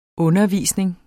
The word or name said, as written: undervisning